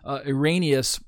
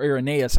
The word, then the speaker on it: Irenaeus: So notice Irenaeus, or Irenaeus, I've heard it pronounced both ways, Irenaeus or Irenaeus.